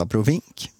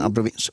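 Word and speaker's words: abrovinck: Kan också uttalas abrovink, men abrovinch.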